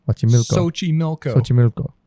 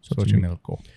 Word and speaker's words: Xochimilco: The region of the how did you say it again? Xochimilco. They have a little typo here. – Xochimilco.